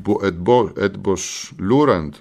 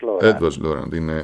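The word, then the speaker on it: Eötvös Loránd: Από το 1635 υπάρχει το Edbos Lourand, είναι και δύσκολο να προφερθούν τα ουγγρικά. Τον καλημερίζω και τον ευχαριστώ. Έτσι προφέρεται, Edbos Lourand. – Edbos Lourand.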